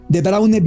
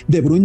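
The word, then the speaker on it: De Bruyne: Porque en el común de los países que tiene una generación con esos nombres, de Braune bien pronunciado como lo hace Dani, que es de Bruin, normalmente en flamenco, en irlandés todas las vocales que usted no vea son las que se pronuncian.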